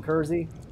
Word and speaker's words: Kersey: I'm on Twitter, just my name, Jason Kersey. Rhymes with Jersey.